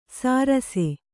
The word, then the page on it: ಸಾರಸೆ: sārase